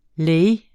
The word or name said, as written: leu